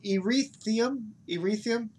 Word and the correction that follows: Ethereum: Erythium? Ethereum. How do you say it? – Ethereum.